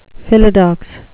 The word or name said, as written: philodox